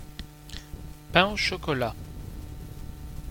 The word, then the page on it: pain au chocolat: France; [pɛ̃ o ʃɔ.kɔ.la]